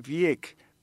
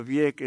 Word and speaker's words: Wieck: El apellido de Clara, que fue bautizada Josefina primero, se escribe W-I-E-C-K. Así que sería Wieck, pero no se pronuncia Wieck en alemán, sino Wieck, estirando un poco la letra I.